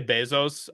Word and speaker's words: Bezos: I say Bezos.